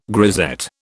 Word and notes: grisette: British English

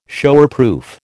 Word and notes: Showerproof: American English

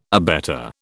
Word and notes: abetter: British English

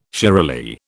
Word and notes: shiralee: British English

